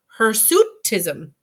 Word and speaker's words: hirsutism: A physician that I worked with said, it's like a suit. Hirsutism. Hirsutism. – Oh, I think I did say it right. Hirsutism.